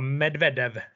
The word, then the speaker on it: Medvedev: Kan det vara Medvedev? exakt ja! – Magiskt uttal från din sida också.